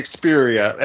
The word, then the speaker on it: Xperia: The Sony Ericsson, if I'm pronouncing this correctly, the Sphere. – Xperia. It's the Xperia.